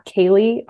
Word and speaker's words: Kaely: So I do almost everything through Instagram, and my handle is at Kaely RD, and my name is spelled weird. It's K-A-E-L-Y and then RD.